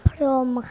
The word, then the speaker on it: พรหม: คำที่มีหอหีบที่ไม่ออกเสียงที่อยู่กลางคำค่ะน้องเจ้านายค่ะคำศัพท์คือคำว่าพรหมครับ